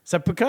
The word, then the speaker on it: Seppuku: Seppuku. Seppuku? Is that how they pronounce it? – Yeah, so that's...